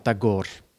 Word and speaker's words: Tagore: Ik denk dat wij het uitspreken als Tagore, maar Indiaanse collega's zeggen Tagore.